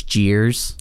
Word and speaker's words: Jeers: So the main character's name is Jeers. – Yeah, yeah. – Cheers. It's spelled like Cheers, but with a J.